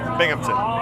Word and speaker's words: Binghamton: Hey, this is Tim Nordwin from the band OK Go, and you're listening to WHRW Bimington. Is that right? – Binghamton. – How do you say it? – Binghamton. Yeah. – Oh, I spelled it wrong. How do you spell it? – B-I-N-G.